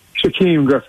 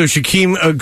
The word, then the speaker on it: Shaquem: Is it Shaquan Griffin? Am I pronouncing that correct? – Shaquem Griffin. – Okay. Well, yeah. I don't know how I got that wrong. All right. So Shaquem Griffin.